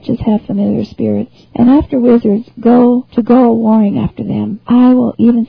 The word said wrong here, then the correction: whoring: I'll read that again because I pronounced one word wrong. I said warring instead of whoring.